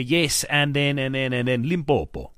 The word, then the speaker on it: Limpopo: I'm not even from Limpopo, but I get annoyed when people pronounce Limpopo and they go Limpompo.